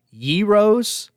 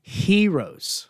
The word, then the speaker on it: gyros: It's actually pronounced like you're saying hero, but with a little Y in there. The G makes a sound, so it's pronounced heroes.